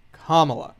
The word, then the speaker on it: Kamala: Excuse you, it's Kamala. – Kamala. – Kamala. – Kamala. – The emphasis is on the first syllable.